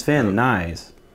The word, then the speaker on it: Van Nuys: But it's Van Nuys.